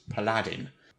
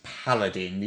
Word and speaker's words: paladin: The next one is, I haven't heard this often, but sometimes the word paladin I've heard pronounced as paladin. – with Aladdin. – Yeah, yeah. it doesn't rhyme with Aladdin. It's paladin. The emphasis is on the first symbol.